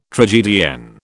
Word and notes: tragedienne: British English

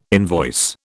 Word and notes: invoice: British English

